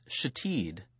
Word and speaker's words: Shithead: It was pronounced Shateed, but was in fact spelled Shithead.